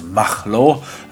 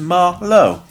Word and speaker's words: Marlow: I pronounced it as Machlo, accentuating the German, but of course it's just Ma-Lo. Yes, well there you go. Ma as in blah, and low as in how low can you go, is what they described it as, which is jolly nice.